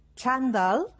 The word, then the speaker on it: xandall: Així que si busqueu el DIEC, el diccionari de l'Institut d'Estudis Catalans, xandall, amb accent a la A, que és com les teories, amb aquesta pronúncia, doncs no t'hi apareixerà. Senyal que no en podem dir així.